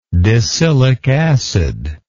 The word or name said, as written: decylic acid